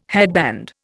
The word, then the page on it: headband: Indian